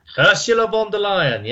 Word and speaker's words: Ursula von der Leyen: The leader of the EU, Miss, I may mispronounce her name, Miss van der Leer. – Ursula von der Leyen, yes.